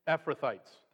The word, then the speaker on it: Ephrathites: Ephrathites? How do you say it? Ephrathites. Thank you.